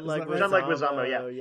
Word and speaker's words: Leguizamo: How do you say his name? – John Leguizamo. Yeah.